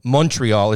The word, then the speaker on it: Montreal: But the Montreal tie-in to me is pretty interesting because the draft is coming up, and it's going to be in Montreal, or Montreal, as they tell me I'm supposed to pronounce it.